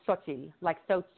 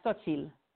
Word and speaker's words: Xochitl: Okay, so the way that it is spelled when I use it in the book is Xochitl, like so chill, but so chill. – Uh-huh. – Like Xochitl. Xochitl. It's like you say chill fast, Xochitl.